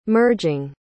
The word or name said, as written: merging